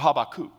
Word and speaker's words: Habakkuk: In the New Testament, they're quoting this obscure prophet you probably never heard of, and you can't even pronounce his name when you read it, Habakkuk, or Habakkuk, if you want to be official, or whatever you want to call him.